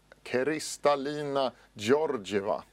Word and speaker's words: Kristalina Georgieva: Och sen har IMF, Internationella valutafonden, fått en ny chef, Kristalina Georgieva. Är det rätt uttalat? – Ja, det tror jag. Det lät mycket bra.